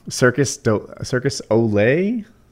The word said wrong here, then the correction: Cirque du Soleil: Cirque du Soleil? – Say low. – Cirque du Soleil. – I always say Circus Ole.